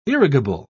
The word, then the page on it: irrigable: British English